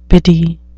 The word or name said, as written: biddy